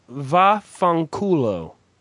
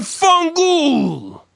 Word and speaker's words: fanculo: It's va fanculo. But you people, and I mean the gutter Italians, you, have turned it into a fungul. – Fungul, G-O-O-L, ghoul? – Fungul is what they say.